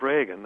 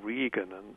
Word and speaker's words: Reagan: Much as people used to do with Ronald Reagan, they would pronounce it Regan, and knowing that that would drive Reagan intimates crazy, although he couldn't care less himself.